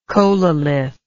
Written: chololithic

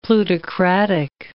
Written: plutocratic

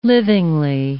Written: livingly

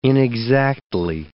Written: inexactly

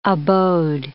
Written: abode

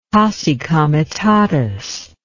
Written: posse comitatus

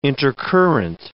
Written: intercurrent

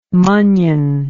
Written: munnion

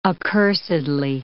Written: accursedly

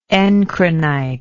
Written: encrinite

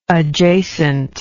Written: adjacently

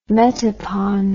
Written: metopon

